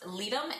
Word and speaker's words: Ledum: I believe it's pronounced Ledum, L-E-D-U-M.